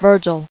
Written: Virgilian

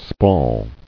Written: Spall

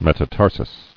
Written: Metatarsi

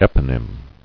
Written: Eponymic

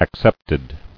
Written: Acceptedly

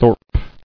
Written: Thorpe

